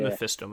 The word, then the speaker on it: Mephisto: Is it Mephisto? Yes. Am I pronouncing that right? – Yeah. Mephisto.